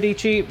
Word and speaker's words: Dietschy: Her name is Sara, Dietschy, rhymes with peachy.